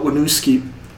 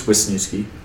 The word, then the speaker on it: Wisniewski: And then they did sign, this is the biggest one in my opinion, Stefana Wisniewski. Wait. Did I say that right? – I think it's Wisniewski. Because there's an S before the end.